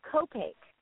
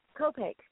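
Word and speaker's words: Copake: And is that called Kopake? Is that how you say it? – It's called Kopake. Kopake.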